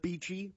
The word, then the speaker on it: Beachy: Beachy. – Beachy, like the ice? – Like B-E-A-C-H-Y is how it's spelled.